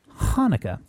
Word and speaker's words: Hanukkah: But Bed Bath & Beyond, and here's why I bring it up, because you were mentioning Hanukkah.